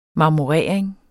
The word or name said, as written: marmorering